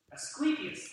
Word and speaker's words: Asclepius: The first one was dedicated to worship, I knew I was going to mess up the pronunciation of this, the Greek god Asclepius. Asclepius. I'm butchering it, I'm sure. Asclepius, is that what it is? Asclepius, that's what it is.